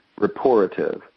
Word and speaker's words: Rapportive: It's spelled R-A-P-P-O-R-T-I-V-E, Reportive. It's like rapport.